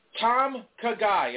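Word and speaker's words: Tom Ka Gai: Tom Kagai. If you're trying to spell it, it's three words, T-O-M, okay.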